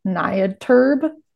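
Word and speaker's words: Niatirb: But in that book, he describes this country of, how to pronounce it, it's Britain backwards. So it's called Nyadterb.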